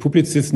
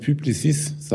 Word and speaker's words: Publicis: von Ihnen gelernt, dass dass ich Publizist nicht so aussprechen sollte, sondern Publicis sagen sollte.